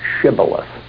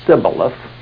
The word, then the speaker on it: Shibboleth: And if he said, "Oh, no," they said, "Say Shibboleth." And you remember he said Sibboleth, if he was an Israelite, because the dialect of the northern kingdom used S instead of SH as the pronunciation of that letter.